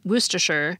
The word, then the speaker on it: Worcestershire: It's pronounced Worcestershire, but it's spelled W-O-R-C-E-S-T-E-R-S-H-I-R-E, so more like Worcestershire.